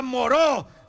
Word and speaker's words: Moreau: Doctor Murró, Carmen. – Se pronuncia Moreau. ¡Doctor Moreau!